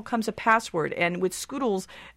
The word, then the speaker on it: Skoodlez: Okay, yes, Macy's getting into social computing, called Scoodles. But it's spelled strangely, S-K-O-O-D-L-E-Z. – Yeah, it's spelled Scoodle Z.